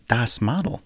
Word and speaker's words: Das Model: We also heard from Kraftwerk in that set with their track The Model, or Das Model, if you want the German pronunciation.